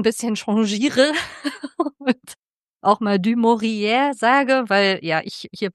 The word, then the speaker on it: du Maurier: Es ist tatsächlich bei der Recherche mir aufgefallen, dass sie selbst, obwohl sie in London geboren wurde, ihren Namen französisch ausgesprochen hat. Da habe ich so ein bisschen überlegt, wie ich das ausspreche. Also erstmal dachte ich, okay, ich muss es Englisch aussprechen, weil sie ja aus England kommt. Mit französischen Wurzeln, deswegen habe ich am Anfang immer die ganze Zeit so dieses du Maurier im Kopf gehabt und habe jetzt aber über die Recherche rausgefunden, dass sie es selber wohl lieber französisch ausgesprochen hat.